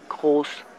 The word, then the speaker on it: Groß: Groß. – Wie schreibt man das? – G-R-O-S.